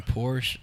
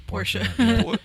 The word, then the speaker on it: Porsche: That's why I never say Porsche. – I think the German pronunciation is Porsche.